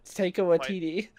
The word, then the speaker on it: Taika Waititi: And Waikatiki character... – Waikatiki? How... – Whatever his name is. How do you say it? – Taika Waititi.